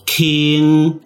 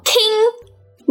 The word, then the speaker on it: 虹: 嗯，很多人是会这样直接国语翻台语的。不过呢，正确应该要念作 “king”。 – 那你的闽南语怎么念啊？ – 你哦，哦，有点难念哦。它念作“ 耶”，跟“ 美耶藤”的“ 耶” 发音是一样的哦。 – 红念作 “king”，你念作“ 耶”。